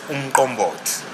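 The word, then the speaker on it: umqombothi: So it's, it's pronounced as umkombot. Umkombot. So there's that, uh, there's that kick, yeah, which is uh, a bit challenging for many people.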